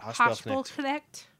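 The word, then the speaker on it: Haspelknecht: Hospital Connect. – Yeah. – Look, did I say it right? Hospital Connect? – Yeah.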